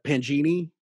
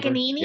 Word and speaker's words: Paganini: Well, he definitely borrowed a lot from what Pangini, mm-hmm. – the very famous clown from Italy or whatever. – Paganini.